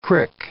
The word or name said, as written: crick